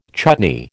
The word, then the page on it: chutney: American English